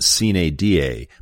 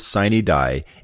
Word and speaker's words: sine die: In Latin, it's actually pronounced sine die, but the legislature says sine die, and when it comes to the legislature, you don't mess with tradition.